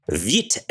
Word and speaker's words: Vite: Vite and Parcel 2.